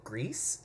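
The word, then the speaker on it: Gris: And then I also picked up a newer game called Grease. I think that's how you say it. It's G-R-I-S. Grease.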